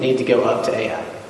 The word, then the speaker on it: Ai: I looked up Ai to make sure I pronounced that correctly.